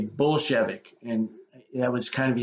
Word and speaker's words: Bolshevik: Now I guess I've always heard it pronounced Bolshevik.